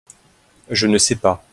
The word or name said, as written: je ne sais pas